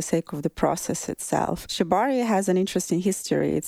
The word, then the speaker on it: Shibari: So I would love to start the practice of, and I don't know if I even pronounce this correct, Shibari. – Shibari, yes.